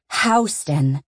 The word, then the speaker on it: Houston Street: I know that Houston Street is pronounced Houston, my friend Maeve had to tell me.